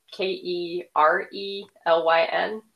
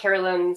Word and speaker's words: Kerelyn: Um, it's K E R E L Y N. Um, smith is spelled normally. Um, and I'm at like Kerelyn Smith or Kerelyn Smith author on most social media platforms, though I'm not particularly good at social media.